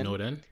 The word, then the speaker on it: Snowden: Edward Edward Snowden. Snowden.